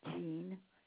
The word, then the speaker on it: Jean: Jean, J-E-A-N, Halstead.